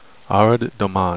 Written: arad domon

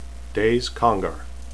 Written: Daise

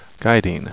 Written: gaidin

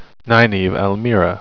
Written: nynaeve

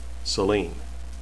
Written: selene

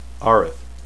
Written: aryth

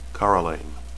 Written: caralain

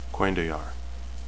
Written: cuendillar

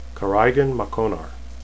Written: caraighan

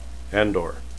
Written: andor

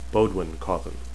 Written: bodewhin

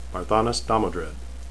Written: Barthanes

